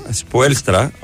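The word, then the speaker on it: Spoelstra: Λοιπόν, πήγε στον Έρικ Σποέλστρα, αν προφέρετε σωστά, γιατί είχε όμικρον και έψιλον μαζί.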